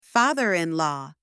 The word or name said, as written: father-in-law